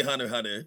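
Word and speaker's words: Hunter × Hunter: But I now say Hunter Hunter because uh, chat corrects me a lot. I, I, say anime character name wrong, so they they they used to fix it for say, me. Like the the Silent X is also getting me on another se- series.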